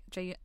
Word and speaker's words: Jaehyun: And then my other one is Jaehyun. I think Jay, is that how you pronounce his name? – Oh, yes.